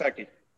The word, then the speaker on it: Gesicki: This Gesicki for Miami. Is that how you say his name? I don't even know. – Yep, Penn Mike State. Gesicki.